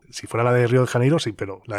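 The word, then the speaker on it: Rio de Janeiro: Pues volvemos a Río de Janeiro, como se suele pronunciar en castellano. Río de Janeiro, podríamos pronunciarlo perfectamente en gallego. Río de Janeiro, más o menos dicen por allí.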